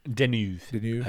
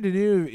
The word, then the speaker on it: Deneuve: Deneuve, I would say. – Yeah, so, like, Catherine Deneuve is a new actress.